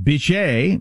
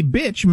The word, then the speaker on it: Bitche: I was going to pronounce it Biche because it's B-I-T-C-H-E, but the E is silent. It's actually pronounced Bitche, I believe.